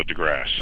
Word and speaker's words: DeGrasse: The Pluto Files by Neil, is it DeGrasse or DeGrassi? Or? – It's a silent E, so DeGrasse.